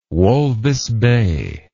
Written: Walvis Bay

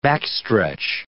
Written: backstretch

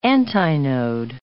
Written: antinode

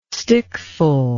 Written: stickful

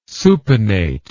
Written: supinate

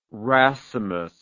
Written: racemous

